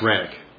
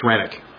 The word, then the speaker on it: Renwick: Upon his return to Scotland, he preached to the field meetings of the Cameronians after the arrest of Renwick. And I've heard it's pronounced Rennick, so I've been pronouncing it wrong for 2 weeks. It's Rennick.